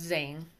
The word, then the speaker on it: Tzain: his name is spelled t-z-a-i-n but it's pronounced zane it's a whole other country or language.